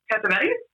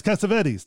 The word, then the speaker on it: Cassavetes: Cassavetes? – Yes, Cassavetes.